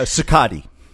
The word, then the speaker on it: Cicotte: Kikot. Is that how you say it? – Uh, Cicotte.